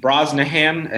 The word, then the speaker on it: Brosnahan: So, Alec, I watched it, and Rachel Brosnahan, I think, am I saying it right? – Yeah, I think that's correct.